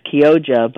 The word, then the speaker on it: Chioggia: So it's pronounced Kyoja, but it's spelled C-H-I-O-G-G-I-A, beets.